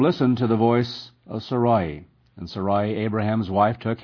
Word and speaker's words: Sarai: Now, Sarai, and this has been pronounced in different ways, Sarai, but it's Sarai, Abraham's wife, bare him no children.